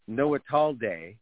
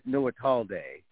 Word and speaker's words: Noitallde: Well, I was told there's a reason why it's pronounced Noatalde, and it's an inside joke because people in Richmond, in Point Richmond, like the way they said it. Anyway, so I'm Noatalde, Gabriel Noatalde.